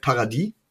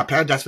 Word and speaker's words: Paradis: Wir reden hier von Matt Paradis oder Paradise oder, ja, Paradise wird es ja nicht ausgesprochen, ist ja ohne E am Ende.